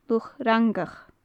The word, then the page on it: luch fhrangach: gaelic